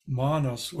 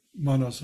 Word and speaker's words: manas: You know, the term manas, which with a long A, not manas as a which means mind as a short A,